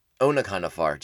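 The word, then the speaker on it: Onaconda Farr: Yeah, so this is when Anaconda Far is realizing... Anaconda Far, jeez. Pronounce the O a little bit harder. Good lord.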